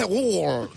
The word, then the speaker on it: Google: Google también lo decimos mal porque la final no se dice E. Se dice Google.